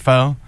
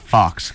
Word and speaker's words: Faux: I think it's pronounced Faux. – Faux? – It's definitely not Fox, because it's spelled. It's like the French thing. – F-A-U-X.